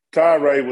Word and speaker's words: Tyra: Yesterday, it looked like AD Vince Tyree. Or Tyra, how do you say his name? – Tyra was headed to Florida State, and everybody was mad at the president.